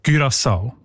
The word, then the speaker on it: Curaçao: Curaçao heisst die Insel, habe ich gelernt. – Sorry? – Mit Ü, genau.